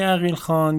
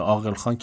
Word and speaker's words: عقیل خان: این آقای عقیل خان یا گفته شده عاقل خان که هر دوتاش تلفظ